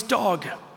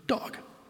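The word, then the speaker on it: dog (דג): Hebrew word for fish, by the way, is dog. That's how you pronounce fish in Hebrew, dog.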